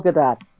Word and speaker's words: Gadot: And to see, you know, and I'm not mentioning your name only because I can't pronounce it. sorry. Gadot? Is her name? Gal Gadot. Thank you.